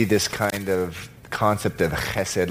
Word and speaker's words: chesed: It's important that you pronounce it correctly. Chesed. Don't say chesed That's not, that would not be right. Chesed. Can everyone say that? Chesed. Very good.